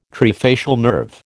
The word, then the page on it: trifacial nerve: American English